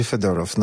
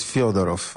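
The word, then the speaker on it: Fedorow (Федоров): W wymowie ukraińskiej to będzie Fedorow. Natomiast ci wszyscy, którzy mówią w języku rosyjskim dla różnych serwisów, blogów i kanałów internetowych, używają rosyjskiej wersji i to jest Fiodorow.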